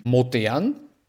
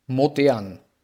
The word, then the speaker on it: modern: Dann gibt es noch den Homograph, Wörter, die gleich geschrieben werden, aber unterschiedlich ausgesprochen werden können und unterschiedliche Bedeutungen haben, zum Beispiel modern, modern. Also modern. Modern und modern. – Okay, ja. Modern, ja, wie okay. Alt und modern wie Schimmel.